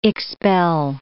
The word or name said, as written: expel